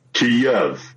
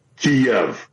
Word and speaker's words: Kyiv: Now, the Russian pronunciation is Kyiv. The Ukrainian closest approximation that we can do as non-Slavic speakers is Kyiv. So the Y ends at the first syllable.